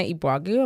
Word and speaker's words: Eboagu: And then if you just type in Hannah Ibuagu, and my last name is spelled E-B-O-A-G-U, you can find me anywhere.